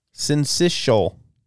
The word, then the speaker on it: syncytial: Oh, it's syncytial, even though it's spelled weird.